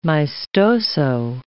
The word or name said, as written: maestoso